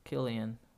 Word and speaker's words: Cillian: Cillian. Yeah, Cillian. Cillian. – It's spelled weird. It's spelled like with a C C I L.